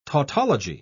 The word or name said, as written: tautology